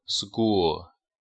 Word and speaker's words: school: 好，下一个学校，读 school。其实这个单词，就说你看音标它是 K，看到没有 SKU，音标是 K，但是呢我们读的时候要读成 G，就是 school。